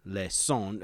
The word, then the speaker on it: leçon: The word lesson comes from the old French lejon, leçon. It is a S sound because it has the little curly Q under the C, forgive me, which came from the Latin lectio.